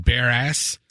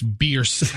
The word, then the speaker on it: Beers: Well, it was an accident on Bear Ass, and the family contacted me and goes, please pronounce our family's name correctly. It's Beers, as in fierce with a B.